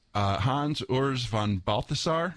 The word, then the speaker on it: Hans Urs von Balthasar: Oh, yeah, I've got a quote from Hans Urs von Balthasar. – You stuck the landing on that. – Did I say that right? – Yeah, you're a real theologian.